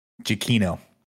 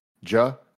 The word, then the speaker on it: Giacchino: in this one was by Michael Nick Giacchino. And now we have looked into it. We went to Twitter. – Mm-hmm. – His Twitter in his bio says, My last name is pronounced Giacchino. So Giacchino.